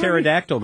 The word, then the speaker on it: pterodactyl: A pterodactyl going to the bathroom? Because the P is silent.